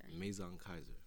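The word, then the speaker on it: Maison Kayser: But that was just so with through this experience, you said Maison Kaiser. How you pronounce it? – Maison Kaiser.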